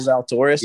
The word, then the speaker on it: Zalatoris: I do have – so I have one guy I do like, top 20, Will Zalatoris. Is that how you say it? Yeah. Will Zalatoris.